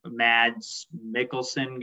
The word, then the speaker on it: Mads Mikkelsen: I'm a huge Casino Royale guy, so Mads Mikkelsen getting cast was huge for me.